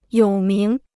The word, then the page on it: 有名: yǒu míng